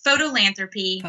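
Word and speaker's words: Fotolanthropy: They have a documentary company called Photoanthropy. Is that how did I say it right? – Yes. Photolanthropy. – Photolanthropy. – Yes. – Yeah. – And it's spelled F-O-T-O lanthropy. It's not P-H.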